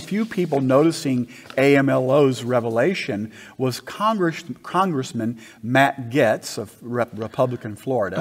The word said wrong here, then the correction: Gaetz: I think you pronounce it Gates.